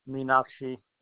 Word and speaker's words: Meenakshi: Meenakshi. – Is it possible you could spell that for me? – M-E-E-N-A-K-S-H-I. – M-E-E-N-A-S-H-I.